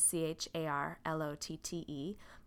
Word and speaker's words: Charlotte: The way my name is written is C H A R L O T T E, but my name is pronounced. I'm thinking of a name that starts with sh and ends with t.